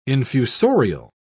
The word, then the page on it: infusorial: British English